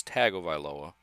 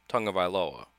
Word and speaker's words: Tagovailoa: Because, you know, if you just say it how it's spelled, it's Tagovailoa. But apparently, Tagovailoa.